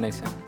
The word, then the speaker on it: nascent: Nascent friendship. – What's that? Nascent. – Nascent. It's basically like starting a friendship. It's a friendship that is spelled nascent. – N-A-S-C-E-N-T.